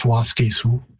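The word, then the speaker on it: Waskesiu: And in Woods Cree, which is the territory where it's located, Wawaskisu. What's happened there is simply the repeated syllable at the beginning has been lost. So instead of Wawaskisu, it's just Waskisu. The pronunciation in Cree, again, varies at the final from Siu, S-I-W as I would spell it, but you definitely get that I-W sort of merging to more of a Su sound.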